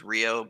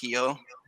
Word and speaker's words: Rio: Like, for instance, an R, like Rio, everybody says Rio, but here they pronounce it Hio.